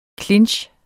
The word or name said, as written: clinch